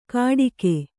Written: ಘಟಿಗೆ

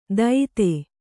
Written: ದೈತೆ

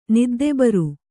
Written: ನಿದ್ದೆಬರು

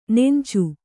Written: ನೆಂಚು

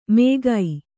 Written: ಮೇಗೈ

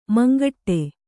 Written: ಮುಂಗಡೆ

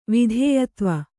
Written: ವಿಧೇಯತ್ವ

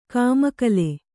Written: ಕಮ್ಮಗೋಲ್

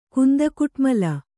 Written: ಕುಂದಕುಟ್ಮಲ

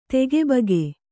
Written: ತಗಿಬಿಗಿ

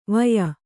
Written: ವೆಯ

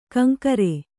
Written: ಕಂಕರೆ

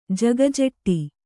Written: ಜಗಜೆಟ್ಟಿ

